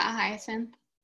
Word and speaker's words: hyacinth: He worked, I know specifically with, I don't know how you pronounce this, the hyacinth hippo? – Oh, I think you got it right. Yeah, hyacinth.